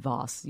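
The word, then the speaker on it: kvass: In English, it's K-V-A-S-S. And I asked the guy how to pronounce it, and he said Voss. You don't, the K is kind of silent.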